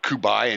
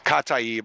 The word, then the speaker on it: Kataib: He was a general of the PMF, the Popular Mobilization Forces within Iraq, the Kataib Hezbollah militia, which, thanks everybody who did not correct me on mispronouncing Kataib. I had been calling it like Kubai and shit, and who knows, I fucking don't know where I got that from. It's Kataib Hezbollah.